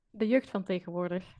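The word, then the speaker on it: De Jeugd van Tegenwoordig: The Jeugd van Tegenwoordig.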